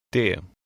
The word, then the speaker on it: det: The final T in number 5 is also not pronounced. Try to read number 5. – Det.